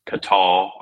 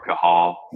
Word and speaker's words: Cathal: Uh, so I I saw the uh, the word cathal, and some people will say it's pronounced catal or cahal, but you know what? It's my affiliate. I'll pronounce it however I want to.